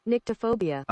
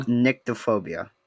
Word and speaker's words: nyctophobia: Nicotophobia. How to say? Please stop. How do you say nicotophobia? – Nyctophobia. – Oh no, I got it wrong. – Got it wrong. – Nyctophobia.